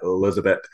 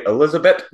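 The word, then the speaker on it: Elisabet: And if I have to say Elizabeth one more time and remember not to pronounce the TH, I think I'm going to scream.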